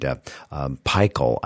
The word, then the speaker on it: pikel: Now, I know that there's, if you look it up in the Oxford English Dictionary, you'll find pikel, I think is how it's pronounced, P-I-K-E-L, Middle English word, which means a spicy sauce or gravy served with meat or fowl.